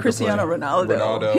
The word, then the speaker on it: Cristiano Ronaldo: How do you pronounce Cristiano Ronaldo.